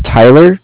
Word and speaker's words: Tyler: My name is Tyler, T-Y-L-E-R. – Mm-hmm. – And I'm calling with Cardiff. It's spelled C-A-R-D-I-F-F as in Frank Frank.